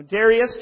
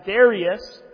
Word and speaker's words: Darius: Now Darius, just as a brief side point, is pronounced either Darius, or Darius, or Darius. Even a Minnesota boy like me can't screw up the pronunciation of this very fluid name.